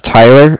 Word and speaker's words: Tyler: My name is Tyler, and I'm calling with Cardiff. – T-Y-L-E-R? – T-Y-L-E-R, correct.